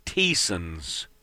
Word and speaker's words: Thiessens: It's spelled T-H-I-E-S-S-E-N-S, but it's pronounced Teason's.